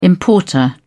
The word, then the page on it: importer: British English